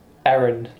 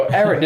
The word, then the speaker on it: Erin: Erin. Like, E-R-I-N, like the like, female name.